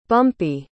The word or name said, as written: bumpee